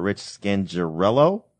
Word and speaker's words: Scangarello: The Broncos hire Rich Scangarello.